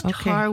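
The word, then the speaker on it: Tár: It's Tar with an accent on the A.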